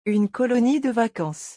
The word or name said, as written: une colonie de vacances